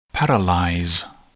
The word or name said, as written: paralyze